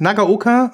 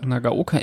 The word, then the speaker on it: Nagaoka: Nagaoka und dann? – Nagaoka MP 110.